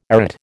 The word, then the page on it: arête: American English